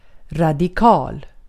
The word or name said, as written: radikal